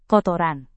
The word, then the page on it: kotoran: indonesian